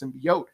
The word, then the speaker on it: symbiote: no, it's not pronounced symbiote, it's pronounced symbiote because of what it is. It's a symbiotic relationship, so you don't say symbiote, you say symbiote.